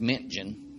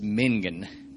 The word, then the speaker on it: Mentgen: So 1980, Mingen, Janet Mingen, the name is spelled M-E-N-T-G-E-N. Looks like it would be pronounced Mintgen, but I looked up the pronunciation and it says Mingen.